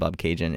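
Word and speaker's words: Bobcaygeon: But the reason why this song is called Bob Cajun is because frontman of The Tragically Hip, Gord Downie, thought that it rhymes with constellation, the word constellation.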